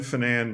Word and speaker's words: Finane: And I had a little column called Ben Finane Music Man. And it was really to encourage correct pronunciation of my last name.